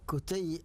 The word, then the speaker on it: كتي: هي كتي أصلا من الأسماء الحجازية القديمة، كلؤي وأبي ويعني قصي، هذه كتي هكذا تنطق.